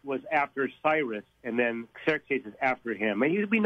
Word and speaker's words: Xerxes: In the Greek, it would be Xerxes.